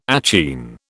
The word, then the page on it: achene: British English